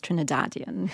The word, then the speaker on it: Trinidadian: But yes, the correct, you have said it correctly, it's Trinidadian.